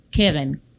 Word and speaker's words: Keren: Keren. Like Karen. – Karen. Okay. – K-E-R-E-N.